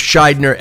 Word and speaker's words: Shidner: No, it was Scheidner, S-H-I-D-N-E-R.